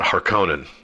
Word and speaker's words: Harkonnen: So the villain in this movie, as we well know, is the Baron Vladimir Harkonnen, and some pronounce it Harkonnen. I think that either is correct, so don't let pedants get in there and get all up in your grill and tell you that it has to be pronounced Harkonnen or Harkonnen.